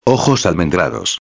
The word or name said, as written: almendrados